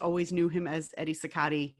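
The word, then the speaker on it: Cicotte: I have always heard his name pronounced Sicati, but apparently it's also pronounced Sicat. – I've heard it Eddie Sicott. – Okay. See, there's like five different pronunciations.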